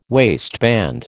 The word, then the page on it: waistband: American English